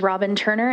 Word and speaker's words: Robin Turner: My name is Robin Turner, and my name is spelled R-O-B-I-N-T-U-R-N-E-R, and I am here on behalf of the Women's Foundation of Montana.